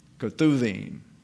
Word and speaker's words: Ketuvim: The B sometimes is pronounced as a V, Ketuvim.